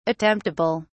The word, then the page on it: attemptable: British English